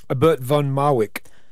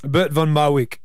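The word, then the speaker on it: Bert van Marwijk: Bert von Marwijk. – Bert von Marwijk, you're going with. – Yep. Silent J.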